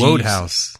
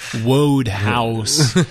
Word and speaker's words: Wodehouse: P.G. Woodhouse. – We did all the G's. Wodehouse. – Wodehouse.